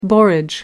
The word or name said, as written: borage